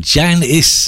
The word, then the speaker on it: Janus: And Fraser tells us that Janice is how it's pronounced, even though it's spelt J-A-N-U-S.